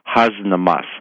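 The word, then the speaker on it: Hasnamuss: What he means by this, and I don't know the derivation of this term husnamus. It's spelt H-A-S, like has, na, N-A-M-U-S-S, husnamus.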